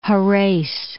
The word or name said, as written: Jerez